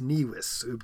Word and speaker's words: nivis: the V comes in. So it'd be nix nivis, the V, or which would be pronounced the W.